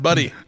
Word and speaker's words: Butte: he worked as an insurance agent in Butte, Montana. – Or Butte. – No, it's pronounced Buddy.